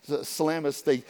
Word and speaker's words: Salamis: Verse 5 says, when they arrived at Salamis, how did you pronounce that? Salamis? Sounds like salami. Salamis, they proclaimed the word of God in the synagogues of the Jews, and they had John to assist them.